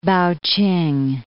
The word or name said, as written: Paoking